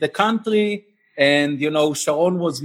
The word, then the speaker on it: Sharon: We're going to talk about it in a second, because I want you to go back to Sharon, because you said it correctly.